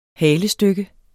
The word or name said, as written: halestykke